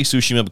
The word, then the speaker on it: Tsushima: Tomorrow, Ghosts of Tsushima, and I say Tsushima because that's how they say it in the game.